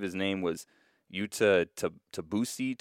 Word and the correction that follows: Tabuse: Tabuse. I'm not sure if I'm saying that right. Um, Tabuse, yeah. yeah, Tabuse,